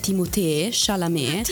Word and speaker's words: Timothée Chalamet: Ha raccontato il trionfo di Timothée, che sto scoprendo adesso si pronuncia in realtà Timothée Chalamet,